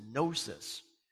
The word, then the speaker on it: gnosis: The Greek word is gnosis, and it's G-N-O-S-I-S. And gnosis means knowledge. So in Greek, the G is silent, and in English, the K is silent.